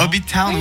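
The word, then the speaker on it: Hobbiton: Hobbitown, oui. Ben, ça s'écrit Hobbit et On à la fin.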